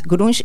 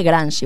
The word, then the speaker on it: grunge: ¿Grunge y noise? ¿Lo estoy diciendo bien? – Sí. – Se dice grunge igual. – Grunge. Claro, se dice grunge.